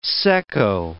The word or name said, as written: secco